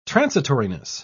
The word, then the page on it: transitoriness: British English